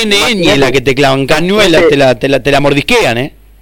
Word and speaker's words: Cañuelas: De repente saber que nosotros ahora el sábado vamos para Cañuelas y que hay gente que le queda lejos. – Bueno, pronunciaste, pará, hay un dato ahí importante, se ríen los chicos acá. Pronunciaste bien Cañuelas.